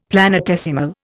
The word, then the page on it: planetesimal: Indian